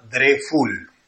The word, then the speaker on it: Dreful: El del tarrito rojo, este producto se llama Dreful, Dre con D, con la D de Dani, Dreful, es fácil de pronunciar, Dreful, el del tarrito rojo.